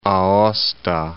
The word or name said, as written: Aosta